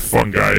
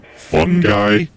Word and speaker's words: fungi: I gotta ask you, fungi or fungi? – Either one would be okay. Both pronunciations will work.